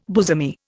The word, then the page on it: bosomy: Indian